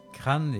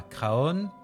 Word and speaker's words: Craon: Alors, si vous vous rendez aujourd'hui à Crâne plutôt, comme on le prononce là-bas, Crâne et pas Craon, je viens de vous dire pourquoi on prononce comme ça.